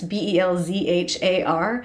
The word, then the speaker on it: Belzhar: I think it's Belzhar is the pronunciation. It's Belzhar.